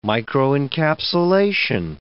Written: microencapsulation

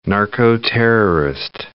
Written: narco-terrorist

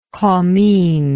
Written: commines

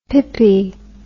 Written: pippie

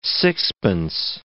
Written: sixpence

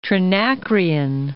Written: Trinacrian

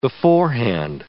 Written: beforehand

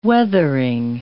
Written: weathering

